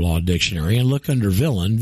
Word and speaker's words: villein: So in Black's, it's spelled V-I-L-L-E-I-N and pronounced villain.